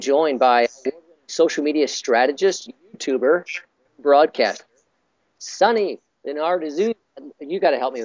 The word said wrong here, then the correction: Lenarduzzi: with I just butchered your last name it's Leonard Lenarduzzi. Leonard Doozy.